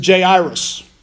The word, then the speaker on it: Jairus: And there came a man named most of us in the Western world, we call him Jairus. Uh, his actual pronunciation is Jairus, which is neither here nor there.